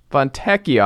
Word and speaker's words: Fontecchio: Simone Fontecchio, I guess, is the right pronunciation. – If it's E-C-C-I-O, it's Eck. Yeah. – Fontecchio.